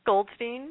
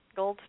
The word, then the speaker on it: Goldstein: Goldstein, you're right. Goldstein.